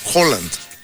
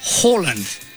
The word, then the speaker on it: Haaland: Y por otro lado, Erling Haaland, que los ya me enteré porque entrevistaron a un noruego, ¿cómo se pronuncia Haaland? Ves que tiene doble A, ¿no? – Erling. – No, Haaland. – Haaland.